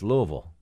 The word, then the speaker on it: Louisville: It's Louisville. Louisville. – I, yeah, not bad. You know, I've never been there. I really want to come down, and uh, but I've always I've always loved Louisville just because I love hearing people say it.